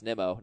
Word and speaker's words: Nimmo: As a leadoff hitter, he has scored 15 runs in 19 games, and Brandon Nimmo is only 8% owned, and yes, it is pronounced Nimmo, not Nemo, sorry.